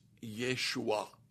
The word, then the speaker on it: Yeshua: The Hebrew pronunciation of the name Joshua is Yeshua. Now, if you'll notice there at the end, it's a sound that we don't make in English. It's called a glottal stop.